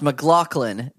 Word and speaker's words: McLaughlin: Pronounced McLaughlin. – That's right. – Yeah, you look up the other guy. – That's right. So J-O-N McLaughlin is really handsome, good-looking dude that writes pop music, incredible piano player.